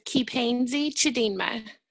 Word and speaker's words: Kipenzi Chidinma: My name is Kipenzi Chidinma.